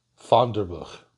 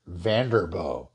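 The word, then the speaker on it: Vanderboegh: That's how you pronounce it in Dutch anyway, right? Fonderbuch. And it's got that hard G thing in the back of the throat. I think people in Holland have a little bit of spit they keep in the back of the mouth for that. Now, Vanderbilt always pronounced it Vanderbo, so that's the pronunciation I'm going to adopt. It's kind of odd. He was very proud of his identity as a Dutch-American, but for some reason adopted this anglicized pronunciation.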